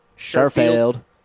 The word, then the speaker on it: Sherfield: Like, how do you mispronounce Sherfield? Sherfield.